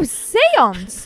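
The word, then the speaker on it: séance: I don't know how full your dance card is, but we've got a the family's got an invitation to this Cianci thing. – Oh, seance! There's a seance here. – Oh, that's how you say it.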